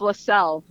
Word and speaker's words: Leczel: So that's spelled L-E-C-Z-E-L. Good luck. – Yeah. Say it again. L-E-what? – L-E-C-Z-E-L. – Is it Lezcal or Lexel? – Close. It's LaSalle.